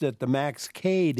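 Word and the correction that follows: Kade: Institute, and I looked up the pronunciation, and it said most Americans pronounce it Cade, but it's not. – That's correct. His name is pronounced Max Kada. – Kada, and that's the German. – That's the German pronunciation, and that's the pronunciation that most of us who are affiliated with entities that have that name go by.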